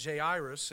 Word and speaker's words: Jairus: a man named Jairus,